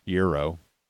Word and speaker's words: gyro: Gyro. Euro. Yeah. You pronounce it not the way it's spelled.